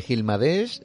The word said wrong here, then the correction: Gilgamesh: de Gilgamesh, perdón, lo he dicho bien,